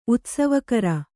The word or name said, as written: ಉತ್ಸವಕರ